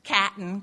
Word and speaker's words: Cotton: Most of these prestigious interviews occurred walking my Bichon Frise cotton, actually pronounced catton because I came from the Midwest, around the block in suburban Chicago before the age of 10.